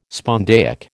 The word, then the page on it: spondaic: American English